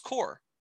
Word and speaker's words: corps: I also just want to say that I just even looked it up, and people very, very frequently use the phrase forward corpse, wide receiver corpse. Like, No. This is not what I'm saying. Are you saying that is, I'm saying C-O-R-P-S. They do. It's pronounced core. That's C-O-R-P-S does not pronounce corpse.